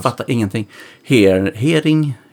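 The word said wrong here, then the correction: Herning: hering? Härning. Härning. härning.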